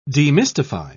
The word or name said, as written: demystify